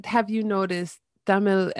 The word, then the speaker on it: Tamil: And am I saying Tamil correctly? – Yes, actually, you're pronouncing it quite right. Like, in the English way, we can say Tamil, but Tamil is actually the more authentic way.